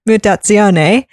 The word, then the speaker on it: Mutazione: So I think I'm pronouncing this correctly. I'm pronouncing it the Italian way. Mutazione is about you play this girl Kai,